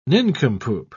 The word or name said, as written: nincompoop